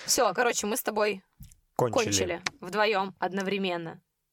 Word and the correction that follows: одновременно: Одновременно. – Это, кстати, одновременно. Это, кстати, тоже очень важно. Не только исправлять ударение своему любимому человеку в словах, но, но и, и кончать одновременно.